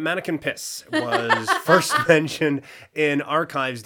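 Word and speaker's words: Manneken Pis: Do you think it's pronounced Mannequin Piece? – as if I didn't Google this to make sure I was pronouncing it right.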